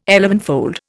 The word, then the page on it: elevenfold: Indian